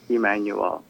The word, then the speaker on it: Emmanuelle: So Yogi, Y-O-G-I, Emmanuelle, E-M-M-A-N-U-E-L-L-E. Spelled like the French, Emmanuelle.